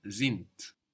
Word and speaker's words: sind: Sind is spelled SIND. So we have the S pronounced like a Z there, and the D on the end of the syllable pronounced like a T.